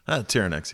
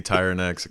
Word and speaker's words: Tyerenex: it can be Tyerenex, it can be Tyerenex. It's just whatever you want to call me.